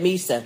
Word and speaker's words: Mhysa: I can't remember now how they pronounce it. – They're saying Misa.